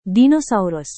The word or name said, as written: dinosaurus